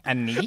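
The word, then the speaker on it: Annis: Something called the Black Anus. – Anus? The Black Anus? – I think it's pronounced Anise.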